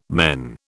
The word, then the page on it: men: British English